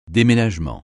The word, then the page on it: déménagement: français